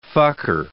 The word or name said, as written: fucker